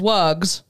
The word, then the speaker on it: wugs: The correct answer is wugs, pronounced with a voiced Z sound as in dogs, because the plural forming letter voice follows a voiced consonant G.